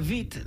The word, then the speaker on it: Witt: Well, it was Katarina Witt, not Katarina Witt. – Yeah. – Well, because the W is pronounced like a V.